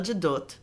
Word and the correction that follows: Gadot: Is that anti-Semitic, pronouncing her name that way? – Was it? Well, her name's Gal, but her last name is Gadot, so...